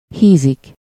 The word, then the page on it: grossir: [ɡʁɔ.siʁ]; France